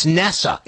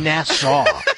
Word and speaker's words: NASA: It's not even pronounced. It's NASA. It's not Nassau.